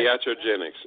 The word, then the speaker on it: iatrogenics: Iatrogenics.